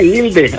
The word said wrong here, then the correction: Ilse: Oh, Ilse, ¿cuál Ilde? Ilse.